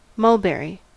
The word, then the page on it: mulberry: American English